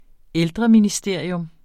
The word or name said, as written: ældreministerium